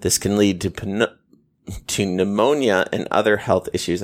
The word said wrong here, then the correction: pneumonia: I tried to pronounce the P in that, didn't I? This can lead to pneumonia.